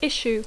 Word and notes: issue: English

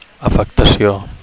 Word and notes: afectacio: Catalan

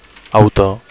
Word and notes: autor: Catalan

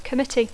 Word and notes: committee: English

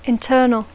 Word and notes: internal: English

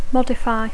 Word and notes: modify: English

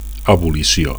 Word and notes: abolicio: Catalan

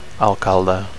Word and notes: alcalde: Catalan

